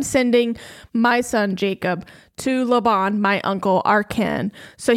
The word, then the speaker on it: Laban: Am I saying that right? – That's how I would say it. – Laban.